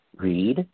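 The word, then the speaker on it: Reade: I think it's pronounced Reed, but it's not Tara Reed, the actress. It's R-E-A-D-E.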